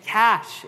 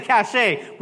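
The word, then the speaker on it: cache: You actually say, someone said cachet. We're just going to pretend like we didn't hear you. I pointed. Someone's like, "Cachet." No. I pointed. My bad. I shouldn't have done that. Cash. It's actually called cash. I say you pronounce it cash, all right?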